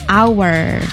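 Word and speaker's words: hour: Jam, so when it's related to time, so actually this should be pronounced with our. – Our. – The H is silent.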